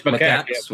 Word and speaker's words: macaques: Apparently, adolescent female Japanese makis? That's how I'm going to pronounce it. Makakis. Yeah, Japanese. So adolescent female Japanese makakis. – Macaques? – It's macaques.